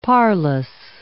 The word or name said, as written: parlous